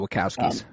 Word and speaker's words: Wachowski: Thinking of what the Wachowski, is that how you say it? – Yeah, the Wachowskis.